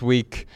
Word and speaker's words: VVE: All right, y'all, for our added value segment this week, let's listen to a song at the end of this episode. It's called Busy, and it's by the band We, which is spelled V-V-E.